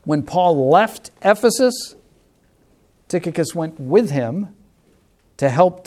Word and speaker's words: Tychicus: English, Tychicus. Ancient Greek, Tychicus. So you decide which is easier for you to pronounce. I'm doing Tychicus.